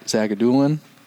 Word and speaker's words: Zagidulin: Goaltending has been red hot for those guys, and uh, I think what's it, is, is it Zag, Zagidulin? Is that how you say it? Yep. – Yep.